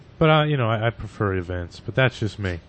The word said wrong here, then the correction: Evince: Is it spelled like it sounds? Evince? – Yes, Evince. And if you're running Debian, it should be a simple app getaway.